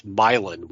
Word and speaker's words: Milan: I've always used the example of, we have a Milan, Missouri, but it's pronounced Milan.